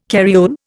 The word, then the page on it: carriole: Indian